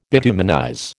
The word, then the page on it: bituminize: American English